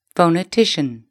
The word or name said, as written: phonetician